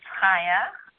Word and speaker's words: Chaya: Haya, C-H-A-Y-A, pronounced.